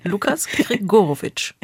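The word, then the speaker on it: Lucas Gregorowicz: Sie sind in einer Beziehung mit einem anderen Schauspieler, haben das öffentlich gemacht mit Lukas Gregorowitsch. Hoffe, ich habe das richtig ausgesprochen. – Fast. – Wie ist richtig? – Nein, das war alles gut. – Lukas Gregorowitsch.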